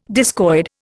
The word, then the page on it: discoid: Indian